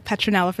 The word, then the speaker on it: Petronella: Okay, and Instagram um, I now have a new handle, Petronella Photography.